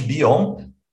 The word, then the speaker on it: Bion: É, a gente está chamando de Bion, para fazer essa referência com a língua inglesa, né?